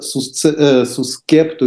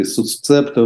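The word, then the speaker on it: suscepto: А речь идет действительно о так называемой войтивной практике, слово это имеет латинское происхождение, эксвоты или по-русски войтивы, эксвота сускепто или сусцепто в разных орфоэпических традициях, это латинское выражение произносится, то есть по данному обету.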